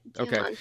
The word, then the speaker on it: Diamante: All right, so Diamante, is that, did I say that right? – Yep, that's it. Diamante.